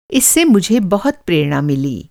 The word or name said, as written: प्रेरणा